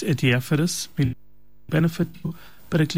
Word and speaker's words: adiaphorous: The word that we were looking for, Malanai, is this word, adiaphorous. Adiaphorous. Adiaphorous is spelled A-D-I-A-P-H-O-R-O-U-S. Adiaphorous.